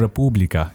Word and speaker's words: República: República. Yeah. – Yes. – Emphasis on the right syllable, as they say.